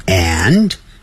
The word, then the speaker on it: and: 好， And A-N-D，这个 A-N-D 啊标准的念法是 and，可是你实际念的时候是念成 n n，它念得很弱，甚至有时候念成 n n n，那个的 a n d 那个的 n 的，它的那个的都不念出来了。